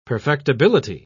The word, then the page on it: perfectibility: British English